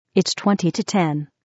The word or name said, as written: 20 to 10